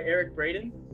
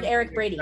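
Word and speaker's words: Eric Braeden: Right, Eric Braden? – Yes, that's right. You got it right. Okay. We heard Eric Braden. – I'm not saying someone's name wrong. – No, you're saying it correctly.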